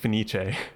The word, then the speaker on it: Fenice: Venice. I called it the Venice Opera House when it actually is pronounced Fenice, translating to Phoenix in English.